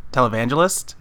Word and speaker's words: televangelist: Okay, I say TV angelists because I can't say that word. TV angelists, like, on, you know, back in the 70s and 80s. But I guess the right way to say it is televangelist, right? – Yes, you got it.